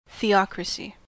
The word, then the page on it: theocracy: American English